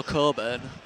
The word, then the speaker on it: Cockburn: if you're going to mention me, it's pronounced without the CK. So that's from Joe Coburn, whose name's spelt Cockburn.